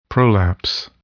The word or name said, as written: prolapse